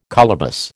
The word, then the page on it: colobus: American English